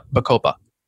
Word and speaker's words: bacopa: I've not heard of, do you say bacopa? – Bacopa, yeah, it's a really good, it supports memory, it supports mental health. So yeah, bacopa.